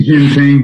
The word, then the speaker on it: Jinping: And you've got the president, you know, Jinping, or I forget exactly how to pronounce it. – Jinping.